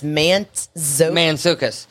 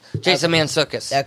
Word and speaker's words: Mantzoukas: It's Mantzoukas. – Jason Mantzoukas. – Okay, Mantzoukas.